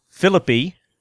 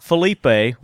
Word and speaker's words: Philippi: It's called We the People, and it's by Ben Philippi. Not Felipe, which I mispronounce it, but it's Ben Philippi.